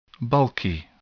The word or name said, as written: bulky